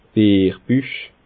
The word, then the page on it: Bierbüch: Bas Rhin; Schiltigheim